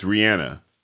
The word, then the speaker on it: Rihanna: It's Rihanna. – And we won't do it. – No. – I try and do it. – Me too. – And then it feels foreign. It feels like I'm saying her name wrong. – Yeah. – I'm not. That's how you're supposed to say it.